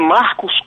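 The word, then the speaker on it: Marcus: O meu nome é Marcos com U, é bom que já que é um programa de rádio, Marcos com U, como se escreve em latim, e Lucena, tem dois N's no final, é MarcusLucenna.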